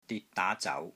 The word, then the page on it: DIT3 DAA2 ZAU2: Cantonese